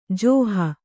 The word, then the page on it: ಜೋಹು: jōhu